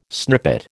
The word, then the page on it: snippet: American English